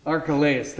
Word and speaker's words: Archelaus: But when he heard that Archelaus, how do I say that? Archelaus, thank you. I knew how to say it, but it blanked.